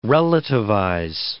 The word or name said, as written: relativize